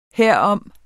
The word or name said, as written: herom